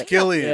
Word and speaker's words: Cillian: Yeah, I thought it was Cillian. – I always say Killian. But it's Killian. – Yeah. – It is? – That's how they say it in Ireland, you stupid bitch.